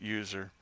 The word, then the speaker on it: usr: And under slash, there are a whole bunch of directories or folders like bin, sys, home, usr, which is usually pronounced user, and so forth.